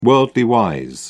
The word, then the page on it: worldly-wise: British English